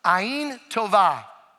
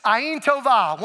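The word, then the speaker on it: Ayin tova (עין טובה): All right, now let me tell you how to say the word good. The way you say the word good is tova. Everybody say tova. One, two, three. Ayin tova. I didn't lead you well. That's my fault, Anderson. Ayin tova. One, two, three.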